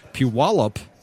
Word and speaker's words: Puyallup: And I'm here in the V-Twin Cafe with my special guest all the way from, are you ready, boys and girls, Puyallup, Washington. That would be Tracy Getty. Tracy, welcome to the show. – And you pronounced it perfectly. Puyallup part, not the Getty.